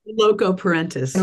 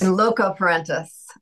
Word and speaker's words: in loco parentis: there's a phrase in there in locus parentis. Is that, am I saying that right? – Loco parentis. – In loco parentis